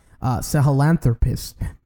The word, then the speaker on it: Sahelanthropus: You know, and then you got Sahelanthropus.